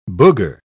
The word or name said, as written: booger